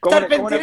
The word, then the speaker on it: Charpentier: Candidata, antes de conversar y eh, empezar a hablar ya en materia política, su apellido se pronuncia Charpentier o Carpentier como el chef. Tengo la misma duda que tú. Charpentier está bien.